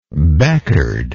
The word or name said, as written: becard